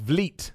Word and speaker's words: Vliet: It's pronounced Vliet. Thank you, my friend. Chris Van Vliet. I will try and remember that. Vliet. So it's like feet. Vliet feet.